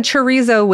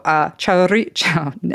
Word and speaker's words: chorizo: We said chorizo with the Spanish pronunciation, and my Basque family was not pleased that we didn't use the Basque pronunciation, okay. – Which is, hold on, it's worth it. It's worth me not getting in trouble. Chorizo is the Basque. You roll the R, though.